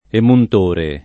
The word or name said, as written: emuntore